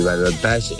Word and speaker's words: balotage: Profesor, equipaje es una palabra española y balotage es una palabra francesa, por eso se pronuncia balotage.